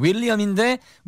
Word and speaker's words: Will.i.am: I like the way he pronounces his name. The spelling is William. – 예, 네, William인데 Will 끊고 나는 Will이거든. 그래서 Will.i.am.